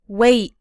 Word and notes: wait: American English

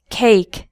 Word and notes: cake: American English

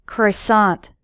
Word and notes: croissant: American English

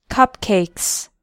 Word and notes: cupcakes: American English

